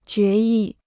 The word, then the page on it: 决议: jué yì